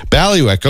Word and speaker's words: bailiwick: B-A-I-L-I-W-I-C-K. – Okay. – So like Bally and then Wick. – Ballywick.